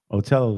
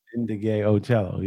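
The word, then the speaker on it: Ndegeocello: How do you pronounce her last name? – Michelle Ocello. Ocello was the last name. – Indigay Ocello. – Indigay Ocello, yeah. – Okay. People pronounce it differently.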